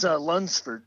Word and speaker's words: Lunsford: It's Lunsford, but...